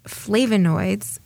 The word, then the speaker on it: flavonoids: Well, I read it as good news, and it's about the impact that some of the flavonoids, and I might mispronounce them. – No, that's exactly right.